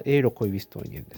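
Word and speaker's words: Eero Koivistoinen: これは正しくは、発音はエーロ・コイビストイネンです。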